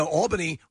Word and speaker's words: Albany: And if you're in New York, you say Albany. – Yeah. – And the Albany with one L. – Yeah. – Mm-hmm. You'd think it'd be Al. – But that's the proper pronunciation.